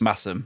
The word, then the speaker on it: Masham: And is it Massam or Masham? I can't remember how you pronounce it. – Massam.